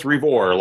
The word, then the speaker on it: Revor: And you can call me by the real name, that's Thomas Revor, long E, long O.